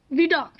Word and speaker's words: Vidocq: Do you mean Vidocq? – Vidocq, yeah, there you go.